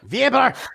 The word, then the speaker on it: Weber: Uh, he basically, maybe I should pronounce it more Germanly. Peter Weber. Weber.